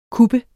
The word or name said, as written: kuppe